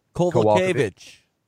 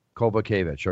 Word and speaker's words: Kowalkiewicz: Then you got Carolina Kowalkiewicz. Help me out, before I go into my rehearsal. – Carolina Kowalkiewicz. – Kowalkiewicz.